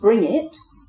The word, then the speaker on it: Branziet: So we've got the B-R-A-N-Z-I-E-T, pronounced Bring It, a farm and settlement near Bardowie in East Dunbartonshire. We've got B-U-N-Z-I-O-N, pronounced Bunyan.